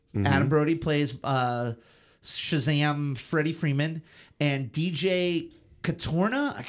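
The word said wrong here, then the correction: Cotrona: how do I pronounce his last name? DJ Katrona, sorry.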